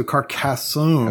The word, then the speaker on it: Carcassonne: Also, Carcassonne.